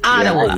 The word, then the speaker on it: Ottawa: It's Ottawa.